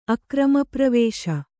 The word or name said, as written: ಅಕ್ರಮಪ್ರವೇಶ